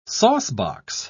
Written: saucebox

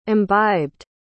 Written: imbibed

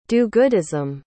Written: do-goodism

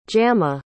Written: jama

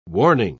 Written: warning

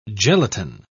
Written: gelatine

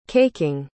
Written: caking

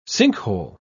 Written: sinkhole

